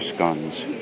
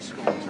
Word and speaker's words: scones: Scones are scones. – Sharon, are they scones or are they scones? – In Ireland. – In Ireland, they're scones. In the UK, they're scones.